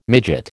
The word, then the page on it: midget: American English